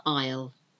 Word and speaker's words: isle: And the other isle, so we've got island, but we've also got the isle, an isle of Sicily, for example. I-S-L-E, isle.